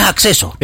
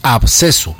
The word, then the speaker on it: absceso: Vea, entonces, no es acceso. – Es absceso. – Absceso, se escribe A, B alta, S, C, E, S, O.